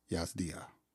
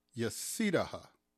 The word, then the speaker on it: Yazdiha: Ladies and gentlemen, it's my distinct honor and privilege to have on this podcast, Hajar Yazdiha. All right, Professor Hajar Yazdiha. Did I say that right? – So close. It's Yazdiha.